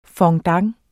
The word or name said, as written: FONDANTER